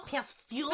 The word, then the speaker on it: Pasteur: Oh, so it's Louis Pasteur. – Pasteur? – Pasteur.